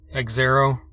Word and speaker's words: Xero: I assume it's Xero. It's X-E-R-O instead of zero, but with an X.